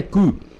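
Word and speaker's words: cou: Quem sabe um pouco de francês sabe que o pescoço em francês é cu. a pronúncia é essa, apesar de não escrever assim, sim, sim.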